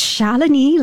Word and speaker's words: Shalini: So it's Shalini, like felony, you know, not Shalini.